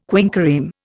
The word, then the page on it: quinquereme: Indian